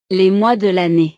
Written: les mois de lannee

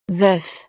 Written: veuf

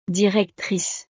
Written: directrice

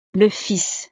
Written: le fils

